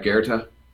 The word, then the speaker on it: Goethe: Am I saying it right, Goethe? – Yes. How I'd say it.